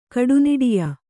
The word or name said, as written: ಕೂಟನಾಟ್ಯ